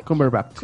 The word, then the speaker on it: Cumberbatch: Cumberbatch. – Cumberbatch.